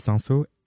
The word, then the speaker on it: Sinseau: En gros, mon qui, ça va être euh, un humoriste slash poète slash artiste slash euh, danseur de voguing qui s'appelle Noam Sanso, que vous connaissez peut-être. Noam, ça s'écrit N-O-A-M et Sanso, S-I-N-S-E-A-U, pour les gens qui nous écoutent.